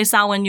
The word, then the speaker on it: Samhain: It is pronounced Samhain. – I mean, if that's what you want. – No, you can say it however you want. I just like to let PSA, because I see it pop up even in, like, media and stuff where people say it wrong, and I'm like, you guys should know better. It's actually pronounced Samhain.